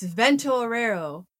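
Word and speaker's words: Vento Aureo: It's Vento Orero, but now that you mention it, that's where we're going to end.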